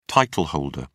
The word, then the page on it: title holder: British English